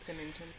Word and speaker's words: Symington: I forget how the British pronounce that. – I think that. – It's Symington.